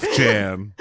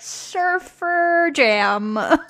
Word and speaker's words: Sufjan: Sufjan? You've never pronounced Sufjan Stevens out loud? – No, I have. I don't know why I did that. – Sufjan. – I just read it and I'm like, Surfer Jam.